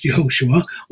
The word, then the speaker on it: Yehoshua: In 13:16, Moses makes a name change, calling Oshea, the son of Nun, Yehoshua, pronounced Yehoshua, or as we know him, Joshua.